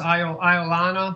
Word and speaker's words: Iolana: Last one is Iolana? – Yes, I believe that's how it's pronounced. Iolana by Milt Raskin.